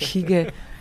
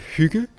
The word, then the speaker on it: hygge: So what is hige, or how you say hike hige? – So we pronounce it hygge, and it's a Danish concept that basically means the art of creating a nice atmosphere.